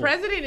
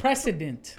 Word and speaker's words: precedent: It's spelled the same. It's said different. – The precedent. – The accent's in a different spot.